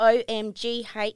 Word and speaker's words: OMGhee: OMGhee Australia. – Yep. – yep, good. So it's G is spelled G H, isn't it? – Double That's e? correct. G H Double E. Double E, yeah.